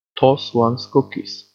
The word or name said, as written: toss one's cookies